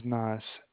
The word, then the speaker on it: Naz: Nas? My name is Nas. – And how do you spell that? – N-A-Z.